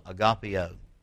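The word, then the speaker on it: agapaō: The second type of love found in the New Testament is agapeo, or agapeo. It's A-G-A-P-A, long O, agapeo.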